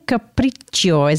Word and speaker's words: Capriccio: Megan Capriccio, is that how I say your name? – Yeah, that's perfect.